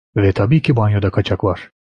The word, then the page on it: tabii: turco